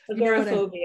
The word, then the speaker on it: agoraphobia: technically, you know, I never pronounce it correctly. not ergophobic. What is it? – Agoraphobia.